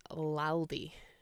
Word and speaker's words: Laldy: This week's word is Laldy. – Laldy? – Yeah. You can spell it L-A-L-D-Y or L-A-L-D-I-E.